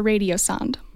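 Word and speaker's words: radiosonde: It's called a radio sond.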